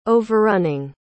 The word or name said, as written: overrunning